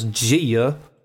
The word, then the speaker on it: Gia: Homie, hooker, Gia, which is the West Coast version of Yia, but it's just spelled with a J, so it's Gia.